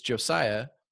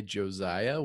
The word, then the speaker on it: Josiah: It's Josiah. – Mm-hmm. – Most people will say Josiah with like a Z sound.